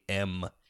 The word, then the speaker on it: Gonjiam: Well, the Gongium, I'm probably not pronouncing that correct. It's Gonjiam Haunted Asylum.